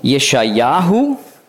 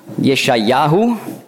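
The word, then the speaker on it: Jeschajahu (ישעיהו): Zuerst zum Namen Jesaja, hebräisch ausgesprochen Jeschajahu. Das ist die Langform, es gibt auch die Kurzform Jeschaja. Jeschajahu.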